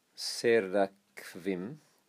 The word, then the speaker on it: Seraphim: The name Seraphim, which is pronounced Seraphim in the Kursk dialect.